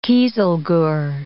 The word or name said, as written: kieselguhr